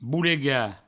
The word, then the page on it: bolegar: boulega